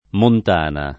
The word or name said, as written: Montana